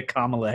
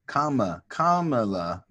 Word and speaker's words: Kamala: Kamala, not Kamala. Where you put the emphasis on the right syllable.